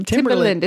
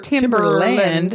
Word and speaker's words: Timbaland: I don't know, but that's the name. Timbaland. It's not Timberland.